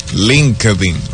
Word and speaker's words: LinkedIn: Hay una red social que se llama LinkedIn.